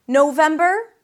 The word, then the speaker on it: November: November, three syllables as well. Same ending, bur. Here we start with no. No, as in I said no. Then them, that's the primary stress. November, November.